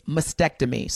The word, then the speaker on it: mastectomy: And I keep mispronouncing this word. It's mastectomy.